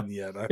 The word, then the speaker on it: vignette: How do you pronounce Vignette? I've been calling it Vignette, but I haven't pronounced it wrong. – Please, yeah. Vignette.